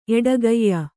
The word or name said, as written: ಎಡಗಯ್